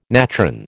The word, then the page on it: natron: American English